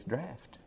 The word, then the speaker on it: draught: I always say draw it, but it's draught. That's the way you pronounce it and everything.